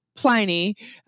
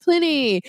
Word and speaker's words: Pliny: It is a long I with one consonant, so that is why we call it Pliny with a long I. Plus, it also rhymes with tiny. So when you order a half pint, you order a tiny Pliny.